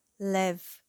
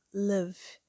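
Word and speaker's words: lev (לב): Há outro termo hebraico para coração, lev, pronunciado love que é a palavra hebraica típica para coração no Antigo Testamento, como em Lamentações 3,33.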